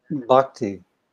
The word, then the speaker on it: Bhakti: Bhati. How do I say that? – Bhakti.